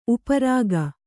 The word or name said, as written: ಉಪ್ಪರಿಕೆ